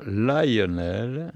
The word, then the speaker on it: Lionel: Lionel, prononcé en américain Lionel, d'où son surnom The Lion, est né en 1908 à Louisville, mort à New York en 2002.